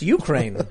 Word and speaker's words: Ukraine: It's like, it's pronounced Ukraine. Huh. I thought it was U-C-R-A-N-E.